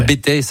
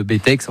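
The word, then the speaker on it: Bettex: Alors le Bétex, certains disent, prononcent pas le X, le Bétex, enfin il y a deux écoles,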